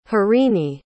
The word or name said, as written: harini